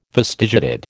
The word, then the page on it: fastigiated: American English